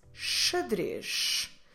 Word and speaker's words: xadrez: The word for chess, the game, in Portuguese is xadrez. Starts with X, but then it ends with Z. Xadrez.